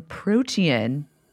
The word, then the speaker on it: Protean: Devin, you have a new piece in Protean magazine. Is that how you say it? – Mm-hmm. It's like a Greek style of protein.